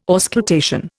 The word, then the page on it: auscultation: Indian